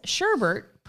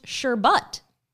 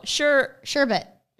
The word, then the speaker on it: sherbet: It says sherbert, pronounced sherbut. – Sherbut. – Have I been pronouncing it wrong my whole life? Look, sherbut. – Well, okay. – Sherbut. – Picture this. Okay, you're saying sherbert. Where is the second R? – But that's how it's spelled.